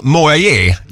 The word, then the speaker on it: Moyet: Vi säger grattis idag till Alison Moyer. Jag är osäker på uttalet på efternamnet. – Ja, men det är rätt. – Det står oss vid M-O-Y-E-T.